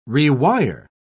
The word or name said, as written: rewire